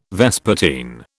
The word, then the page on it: vespertine: British English